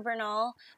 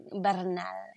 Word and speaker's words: Bernal: You said it perfectly fine right there, Bernal. But if you want to get spicy with it, it's like Bernal.